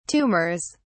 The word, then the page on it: tumors: British English